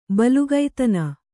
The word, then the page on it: ಬಲುಗೈತನ: balugaitana